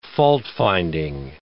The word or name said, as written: faultfinding